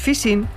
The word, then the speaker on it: phishing: Recuerde la expresión popular, por la boca muere el pez, pues phishing se pronuncia en inglés casi como fishing, nuestra pesca.